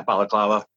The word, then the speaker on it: balaclava: I had a black, like, hood over my face, kind of like a balaclava almost. Yeah, is that how you call it? – You said it right. – Yeah, balaclava.